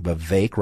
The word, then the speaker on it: Vivek: And that candidate is Vivek Ramaswamy. Vivek rhymes with Lake Ramaswamy.